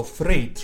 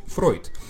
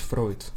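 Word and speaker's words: Freud: Друг мой, дифтонг ЕУ читается как «Ой, Фройд». Ну, конечно, тогда надо, наверное, говорить и «Эйнштейн», но, простите, я как-то так привык говорить «Фройд» в силу того, как вот мне преподавали в институте.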